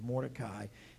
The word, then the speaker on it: Mordecai: Some people pronounce it with four syllables, but I'll refer to him as Mordecai.